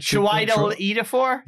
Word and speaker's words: Chiwetel Ejiofor: Chiwetel Ejiofor? – Yeah.